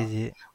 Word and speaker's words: Béziers: Comme la ville de l'Hérault. – Béziers. – D'accord, voilà. Béziers.